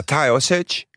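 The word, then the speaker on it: Taoiseach: Tayo Sage. Tayo Sage. – Hoe spreek je dat echt zo uit? – Ja, Tayo Sage.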